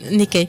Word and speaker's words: Nikkei: Nikkei. De hecho, es una E como muy prolongada.